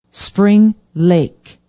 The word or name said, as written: spring lake